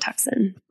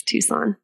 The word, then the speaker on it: Tucson: It is not Tucson. That's how it's spelled. It's Tucson.